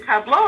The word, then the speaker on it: tableau: Is it tableau or tableau? What is the right pronunciation? – It is a tableau. Tableau.